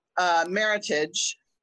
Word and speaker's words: Meritage: Meritage, pronounced like heritage.